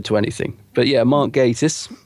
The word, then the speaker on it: Gatiss: And it's, it's, uh, it's written by Mark Gatiss or Gattis. How do you say it? – Mark uh, Mark Gattis